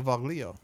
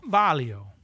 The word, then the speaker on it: Vaglio: I pronounce it different every time, folks. That's right. – Well, Vaglio. – Valio. – Valio. Vaglio. Valio. – I don't know if that gets a hard G. – Vaglio. – V-A-G-L-I-O.